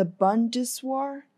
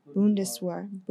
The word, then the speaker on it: Bundeswehr: The announcement came after the chief of Germany's army, the Bundeswehr, that's how you pronounce it. – Bundeswehr.